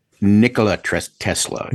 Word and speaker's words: Nikola: Now, on a side note, kind of, do you pronounce it Nikolai Tesla? – I said Nikola. Nikola did not sound right. – Nikola Tesla.